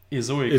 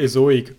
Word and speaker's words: Ezoic: Hi, wir sind von Impact Page und in dieser Episode erfährst du unsere Erfahrung zu Ezoic. Spricht man das überhaupt so aus? – Ezoic. – Ezoic, okay.